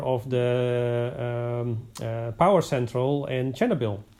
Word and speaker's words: Chernobyl: Chernobyl, and that's exactly how you pronounce it in the Ukrainian language.